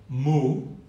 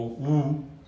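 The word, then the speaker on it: wu (無): La palabra que utiliza Zhao Zhou, mu o wu, en su pronunciación china, frecuentemente se traduce como no.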